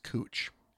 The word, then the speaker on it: Couch: I mean, we have a street in Portland called, it's spelled couch, but it's pronounced cooch.